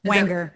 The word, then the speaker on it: Wanger: Wanger, like anger? Wanger. Yeah.